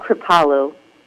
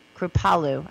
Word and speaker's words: Kripalu: You were a certified Kripala yoga teacher. Did I say that right? Kripala. – It's called Kripalu. – Kripalu. I did that wrong like seven times.